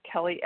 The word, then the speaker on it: Kelley: Hey guys, this is Kelley S., uh, recovered compulsive eater and bulimic in Tulsa, Oklahoma. And my name's spelled with an EY, so Kelly EYS.